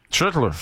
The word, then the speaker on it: Schlittler: Schlittler. J'aime ça de la manière que tu l'as très bien prononcé.